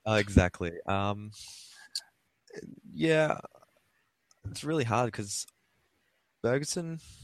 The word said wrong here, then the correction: Bjergsen: Bjergsen, how do you pronounce that? – Bjergsen.